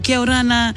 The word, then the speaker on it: kia orana: Kia orana, iteia avatea. – Kia orana. – Oh, Manuia. Is that right? Did I say that correctly? – You said it perfectly.